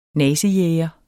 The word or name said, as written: nazijæger